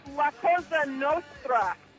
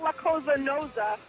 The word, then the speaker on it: Cosa Nostra: By the way, it's La Cosa Nostra, not La Cosa Nosa.